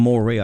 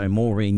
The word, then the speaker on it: Moree: and John is from Moree. I pronounced it incorrectly. Mm. So Moree, New South Wales.